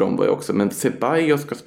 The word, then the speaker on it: Ceballos: Kibalas, Isco och Toni Kroos. – Ceballos där. – Ceballos, ja, förlåt.